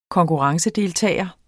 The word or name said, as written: KONKURRENCEDELTAGER